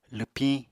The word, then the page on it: lwpiñ: apatani